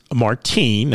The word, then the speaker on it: Martijn: His name is Martine. Now, that's spelled M-A-R-T-I-J-N, and the last name is D-U-I-F-H-U-I-Z-E-N. So, Brother Martine, as a young man of 35, thank you for your witness in creating something beautiful.